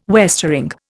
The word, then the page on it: westering: Indian